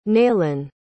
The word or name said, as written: naylun